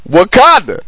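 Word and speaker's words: Waukonda: So when you say it fast, it's going to sound like Wakanda, but it's Wakanda.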